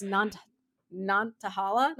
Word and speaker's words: Nantahala: So it's just a hidden gem that you can hike to, and it's a waterfall in the Nantahala. I'm so sorry. I had a pronunciation up in the back. The National Forest, Nantahala.